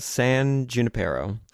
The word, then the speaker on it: San Junipero: which sounds like they're saying it wrong because it sounds like it's supposed to be like a Spanish name, but they yeah. keep calling it San Junipero, and I'm like, okay, oh, hold on a 2nd mm-hmm.